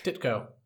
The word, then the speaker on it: Ditko: Ditko. – Ditko, thank you.